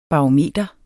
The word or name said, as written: barometer